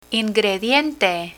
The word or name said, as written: ingrediente